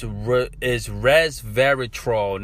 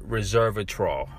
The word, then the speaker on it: resveratrol: It's resveratrol, not reservatrol.